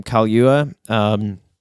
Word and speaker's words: Kaluuya: Kaluuya.